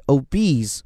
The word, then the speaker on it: obese: 然后它的形容词形式 obese，我们在下面也会看到。而注意这个词的发音，字母 o 发的就是 o 这个音。